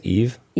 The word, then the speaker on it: Yves: Is that right? Yves Tanguy? – I think it's Eve.